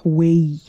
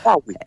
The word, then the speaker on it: Huawei: Huawei. – Yeah, that one. – Huawei.